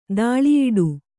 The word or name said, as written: ತಲೆಯುಡೆ